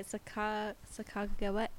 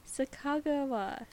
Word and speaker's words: Sacagawea: But in the language that, oh, how do you say it? Sacagawea? Sacagawea? Mm-hmm. The language that she would have spoken, they don't have a J sound. They only have the hard G.